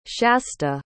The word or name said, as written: shasta